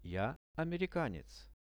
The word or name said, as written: ja amerikanec